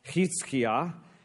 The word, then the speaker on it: Hezekiah: And the guy on the throne at this particular time, from chapters 18 through 20, is a guy by the name of Hezekiah. Now, in Hebrew, his name is pronounced Hizkiah, and it translates Yahweh, or God, is strength.